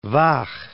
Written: Vah

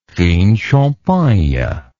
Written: fine champagne

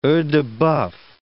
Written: oeil-de-boeuf

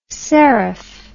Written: seriph

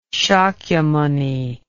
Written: Shakyamuni

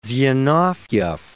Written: Zinoviev

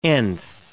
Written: nth

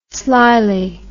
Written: slyly

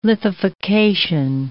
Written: lithification

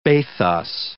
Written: bathos

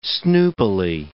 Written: snoopily

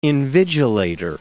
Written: invigilator

